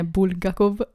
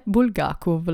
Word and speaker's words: Bulgakov: ho imparato non è Bulgakov, ecco sbagliavo, ma è Bulgakov la pronuncia corretta.